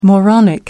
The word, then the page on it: moronic: American English